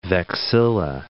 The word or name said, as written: vexilla